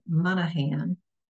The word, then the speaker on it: Monaghan: It is by author Downeth Monahan. Her name is spelled D-A-M-H-N-A-I-T, Monahan, M-O-N-A-G-H-A-N.